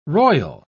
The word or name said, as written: royal